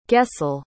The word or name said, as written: gesell